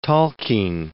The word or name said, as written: Tolkien